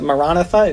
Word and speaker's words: Maranatha: If you've heard of the term Maranatha before, if in Aramaic you pronounce it Maranatha, it means come, O Lord.